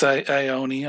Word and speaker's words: Ione: How do you pronounce the alien woman's name? Is it Ione? Or is it? – Yeah, it's Ione.